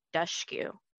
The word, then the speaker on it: Dushku: And let me just say, I just figured out that you pronounce her name Dushku. – I've been saying Dushku this whole time.